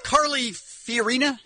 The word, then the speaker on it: Carly Fiorina: Carly Fiorina, am I saying that right? – Yep.